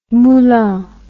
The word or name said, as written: Moulins